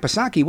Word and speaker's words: Psaki: When pushed back by the media, when she's on CNN and go back, Psaki, what's going on with gas prices? Silent P, of course.